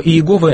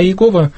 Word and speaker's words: Иегова: То есть Яхве это считается научным больше произношением, а Иегова бытовым, которое вошло со времен Макария.